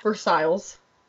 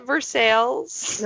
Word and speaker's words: Versailles: Versailles. – And Brittany's literally like, yeah, but we pronounce it Versailles. – Oh yeah, Versailles, okay. Lisa's like along with the rest of America.